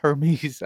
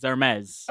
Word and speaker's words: Hermes: You don't pronounce the H. – Yeah. – Mm. – It's Hermes.